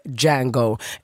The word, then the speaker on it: Django: Alltså, Django Unchained, för de som inte vet, det handlar om en förslavad man som heter Django. The D is silent.